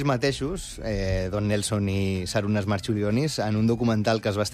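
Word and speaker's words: Marčiulionis: Jo crec que li diuen Marchulionis, però... Perquè que aquesta C es pronuncia com una TX.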